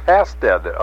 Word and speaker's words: Hastad: And I understand they pronounce Hastad a little different over there. – They don't pronounce the D on the end. It's Hasta.